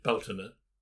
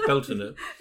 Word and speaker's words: Beltane: And so when you look at the ancient calendar, the solar event, so you've got the 1st of May is Beltane. That's how you say it in Irish. A lot of people say Beltane, which, but anyway, it's pronounced, it's pronounced Beltane.